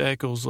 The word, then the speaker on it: Echols: It's Echols like Nichols.